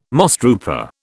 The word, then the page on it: mosstrooper: British English